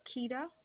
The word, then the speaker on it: Kita: Laura Kita. – Spell that last name for me, please. – K-I-T-A.